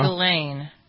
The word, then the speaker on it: Ghislaine: In a moment, as I said, we'll talk about the latest headline from not just Jeffrey Epstein, but Ghislaine, is that how you pronounce her name, Maxwell? – Ghislaine. – Ghislaine, that's how you pronounce it? Okay, it's a French name.